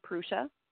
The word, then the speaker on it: Prussia: And you know, I always pronounce it Prussia, but it's actually Prussia. You have to forgive me for pronouncing it wrong all this time.